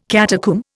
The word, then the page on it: catacomb: Indian